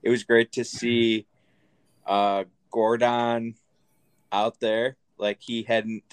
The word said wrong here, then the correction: Goran Dragic: Goran Dragic.